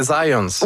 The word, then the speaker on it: Zajonc: Non, c'est Zajonc. – Ouais, OK. – Ouais, j'ai-tu pas dit Zajonc? – Vous avez dit plus Zajonc, mais. – Ah, parce que j'ai prononcé un petit peu à l'anglais. J'ai prononcé mon polonais à l'anglais.